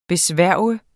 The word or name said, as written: BESVOR